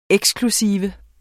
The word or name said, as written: eksklusiv